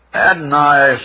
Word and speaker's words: Adonais: Adonais instead of Adonis. Shelley needed that extra syllable.